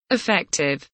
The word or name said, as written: effective